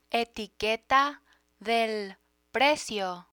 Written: etiqueta del precio

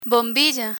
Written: bombilla

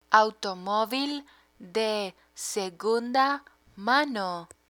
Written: automovil de segunda mano

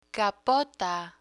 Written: capota

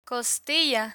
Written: costilla

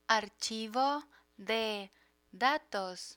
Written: archivo de datos

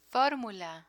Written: formula

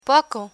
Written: poco

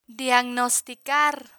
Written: diagnosticar

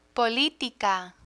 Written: politica

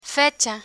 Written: fecha

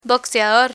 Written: boxeador

